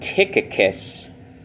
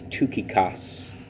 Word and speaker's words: Tychicus: I've always pronounced it Tychicus, but I think it's Tychicus, based on what the Greek says.